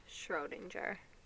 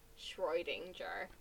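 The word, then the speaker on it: Schrödinger: You're saying it wrong. Schrodinger. You're like Schrodinger.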